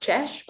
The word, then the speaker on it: Chal: So we get this really interesting sweeping view, and in the foreground we have some rocks called it's pronounced Chesh, but it's spelled Chal.